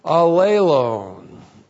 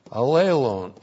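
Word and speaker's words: allelon (ἀλλήλων): The reciprocal pronoun, allelon. Allelon, A-L-L, long E-L, long O-N, allelon.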